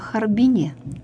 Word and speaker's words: Харбин: Но ни один человек, живший в Китае, никогда не скажет, не сделает ударение вот именно иначе. – Харбин, именно Харбин, в Харбине, да, вот так вот.